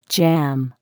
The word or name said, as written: jam